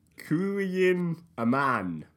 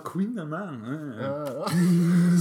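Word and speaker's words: Kouign-amann: Kouyin Aman. That's K-O-U-I-G-N. – K-O-U-I-G-N. Yeah. Yep. – A-M-A-N-N.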